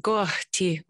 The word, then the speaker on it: Kohti: Kohti or Lavu. Did yes. I pronounce it right? Kohti. – Very good.